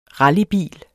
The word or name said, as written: rallybil